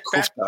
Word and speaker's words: Kuchta: And I'm like, how do you say my name? And she's like, oh, it's Kuchta, you know? So I'm like, okay, I guess we've been saying it right all these years.